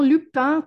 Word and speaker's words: Lupin: Lupin, que é o correto a pronúncia, é Lupin, Arsène Lupin, clássico da literatura francesa também, inclusive, não é?